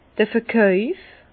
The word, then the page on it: d'r Verkauf: Bas-Rhin; Herrlisheim